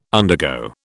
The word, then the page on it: undergo: British English